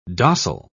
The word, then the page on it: dossel: British English